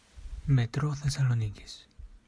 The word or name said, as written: Metro Thessalonikis